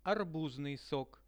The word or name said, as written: arbuznui sok